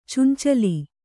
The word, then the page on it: ಚೆಂಚಲಿ: Kannada